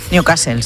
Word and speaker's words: Newcastle: Continúa el goteo de focos de la enfermedad de Newcastle en, lo he dicho bien. – Newcastle, sí.